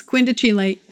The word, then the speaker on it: quindecile: Sagittarius is a beautiful word, but it's quindecile and quintile. – Right. He preferred to put like an Italian pronunciation on the term or something like that.